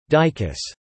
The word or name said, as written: dicuss